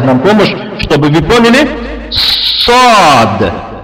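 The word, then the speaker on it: Сад (صاد): Когда произносится эта буква, когда читается, не торопитесь, это немного трудновато. Сад. Вот когда говорите сад, немного надо задерживаться.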